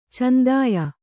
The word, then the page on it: ಚಂದಾಯ: candāya